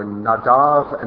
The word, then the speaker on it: Nadav: Chapters 8 through 10 have to do with the priesthood and entering into the holy, and Nadab and Abihu, or Nadav and Avihu, as they're pronounced in Hebrew, are pictures of how not to enter the holy.